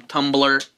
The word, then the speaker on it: Tumblr: I'm not going to Skype it, I'm not going to Instagram it, I'm not going to put it on Tumblr. Which shouldn't be pronounced Tumblr because there's no E, and that makes me mad every day I see that.